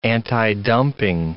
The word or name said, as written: antidumping